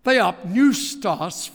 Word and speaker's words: theopneustos: Theopneustos, from theos, meaning God, and pneustos starts with a P, but you don't pronounce it.